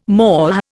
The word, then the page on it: MOH: Indian